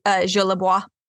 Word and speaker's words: Jolibois: Is it actually pronounced with that French accent? Or? – Yeah, you could say Jolibois, I guess. Jolibois. – Okay. – It is Cajun French.